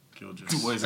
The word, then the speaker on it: Gilgeous: What is it, Gilgeous? – Gilgeous.